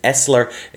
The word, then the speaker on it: SSLER: Cisco pronounces this one Essler. It's spelled S-S-L-E-R.